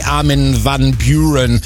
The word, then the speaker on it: Armin van Buuren: Wahrscheinlich ist es genau wie Armin van Buuren. Wird wahrscheinlich komplett anders ausgesprochen. Ich habe irgendwann mal Armin gefragt und er so, sag's im Englischen, dann ist es viel, viel einfacher.